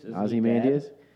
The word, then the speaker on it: Ozymandias: Ozymandian. Is that how you say his name? – Ozymandias? – No.